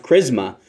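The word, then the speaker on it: chrisma (χρῖσμα): Now, this word for anointing is the Greek word is pronounced chrisma. It sort of, at least initially, the word rhymes with Christos or Antichristos, which is Christ and the Antichrist. So it rhymes with that.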